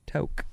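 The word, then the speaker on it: toque: When you see that word, you'll go, oh, right, a toke, like a toke of the doobie weed. – Right. – That's how you say it, toke.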